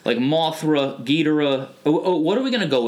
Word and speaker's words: Ghidorah: Ghidorah. – Ghidorah, okay.